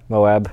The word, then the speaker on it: Moab: Moab. – Oh, okay. – Yeah. Moab.